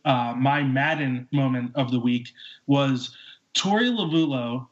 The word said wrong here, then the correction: Lovullo: I said that wrong, didn't I, Ben? Is it Lovello? – It's Lovello.